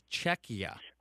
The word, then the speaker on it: Czechia: To say it again, it's Czechia. – Czechia. – Czechia. – Yeah. The I is always pronounced like an E, so it's Czechia.